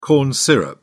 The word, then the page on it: corn syrup: British English